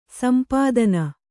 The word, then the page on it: ಸೊಂಪುತನ: somputana